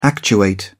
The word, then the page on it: actuate: British English